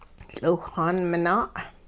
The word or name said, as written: tL'iwhxaan minaa'